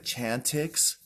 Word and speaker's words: Chantix: Well, not necessarily quit, but I'm taking the Chantix. So it's pronounced chant ix, like icky, caca, poo-poo, pee-pee, icky, oh, icky. So it's like chant ix.